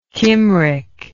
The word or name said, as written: Kymric